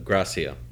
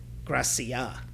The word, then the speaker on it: Gracia: Well, how do you say the manager's name? – Gracia. – Gracia. – Okay. Javi Gracia.